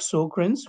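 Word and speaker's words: Sjögren's: And by the way, it's pronounced as Sjogren's, really, like, you know, the Swedish they pronounce, but I think nobody knows how to really pronounce it, like here in US. So we basically will limit us to Sjogren's.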